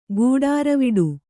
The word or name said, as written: ಗೂಡಾರವಿಡು